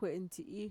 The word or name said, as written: kue'entxi'i